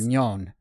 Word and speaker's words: Mignone: My it's Italian. Right? It's Mignoni, Italian. But somewhere along the lines, the last syllable got dropped off. Right. Mignon.